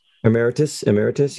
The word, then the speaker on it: Emeritus: How do you say Pope Eremitus? Emeritus. – Yep.